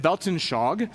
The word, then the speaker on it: Weltanschauung: You actually pronounce it Weltanschauung, which means comprehensive perspective from which we interpret all of reality.